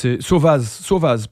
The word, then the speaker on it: Sovaz: Donc c'est Sauvaz, pardon. Ça s'écrit Sauvaz, S-O-V-A-Z.